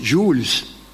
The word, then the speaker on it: Jules: Pero hablan todo, pronuncian Jules.